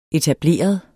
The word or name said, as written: etableret